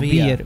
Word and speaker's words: Vier: David, 4 en alemán es Vier. No sé cómo se pronuncia. No, Vier, Vier, Vier.